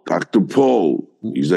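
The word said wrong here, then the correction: Pol: It's Pole with an L? – Yeah, with an L.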